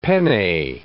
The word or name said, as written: penne